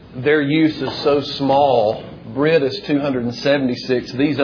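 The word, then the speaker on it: Brit (ברית): But basically, the Hebrew word would be pronounced in our English like Brit.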